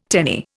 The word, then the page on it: tenny: Indian